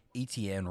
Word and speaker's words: Etienne: Etienne, running back.